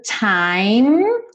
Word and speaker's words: time: Time, sem pronunciar a letra E. Então, não vai falar what's the time, ok?